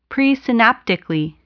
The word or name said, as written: presynaptically